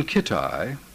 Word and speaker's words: Kitai: Ron Kitai, rhymes with bonsai, is the only Chagrin Falls humanist who remembers who Piano Legs Hickman ist.